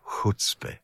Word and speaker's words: Chuzpe: Chutzpe, Chutzpe. Schreibt man C-H-U-Z-P-E und wie erklärt man Chuzpe?